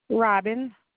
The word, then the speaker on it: Robin: Robin. – And you did say Robin, Robin, so it's R-O-B-B-I-N or it's R-O-B-I-N? – R-O-B-I-N.